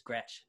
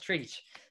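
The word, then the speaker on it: Trietsch: Yeah, you know, since it's German and you know, it has all those consonants in it, everyone thinks it's Gretsch. And I'm like, no, it's Treach.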